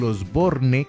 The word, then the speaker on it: Osborne: Vea, existe Osborne, como el de Ozzy Osbourne, que se pronuncia así en inglés. Y en español existe el Osborne, que se pronuncia tal como se escribe.